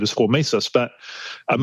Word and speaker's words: hormesis: Well, I want to know about hormesis. Did I get the pronunciation correct? – Yeah.